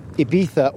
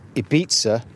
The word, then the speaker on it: Ibiza: My conversation with Roisin was recorded remotely towards the beginning of this month, February 2021, with me in Norfolk and Roisin in Ibiza, or Ibiza, to use the proper pronunciation, where she and her two children, along with her Italian music producer romantic partner, got stuck when lockdown 3 kicked in.